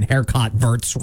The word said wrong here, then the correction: haricot vert: Like we would say it in France, we would say haricot vert. – Oh. – Haricot being French for beans and vert for green. Haricot vert.